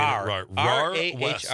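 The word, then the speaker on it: Rahr-West: I don't have my glasses on, executive director of the RAR West. Am I saying that right? – You are saying RAR West.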